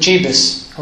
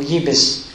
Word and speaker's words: Jebus: That's because before David called Jerusalem Jerusalem, it was called Jebus, or Jebus, probably. Spelt with a J, pronounced as a Y.